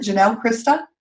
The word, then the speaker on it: Janelle Christa: So is it Janelle, Christa? – Yes, you got it.